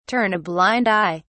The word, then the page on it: turn a blind eye: British English